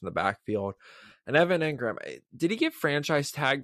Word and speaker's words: Engram: Evan Engram. Am I saying that right? – Yeah, Engram.